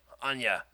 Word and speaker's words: Anya: I say it like a Russian name. Anya.